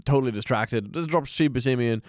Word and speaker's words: Buscemi: Buscemi, not Buscemi. – It's Buscemi? Oh, okay. – Well, that's how he pronounced it. I assume he would know.